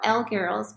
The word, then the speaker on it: Elle Girls: So I am not, I currently am not really blogging, but I'm on Instagram, so L Girls, but it's spelled out ELLE Girls.